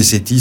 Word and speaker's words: SETHI: Alors je vous le dis au passage, parce que ça ne s'entend pas, mais parce que les H muets, ça ne s'entend pas, mais CETI, ça s'écrit S-E-T-H-I. – S-E-T-H-I, oui, bien sûr.